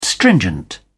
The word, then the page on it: stringent: British English